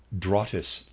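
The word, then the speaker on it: Drottes: The spiritual life of Europe emanated from a mother lodge in Scandinavia, Drottis Lodge, and that's, readers aside, that's spelled D-R-O-T-T-E-S. I am pronouncing that Drottis.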